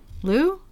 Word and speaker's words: Liu: It's L-I-U. How do you say that? Liu? – L-I-U. – Mm-hmm. Madame Liu. – Liu? I would say Liu.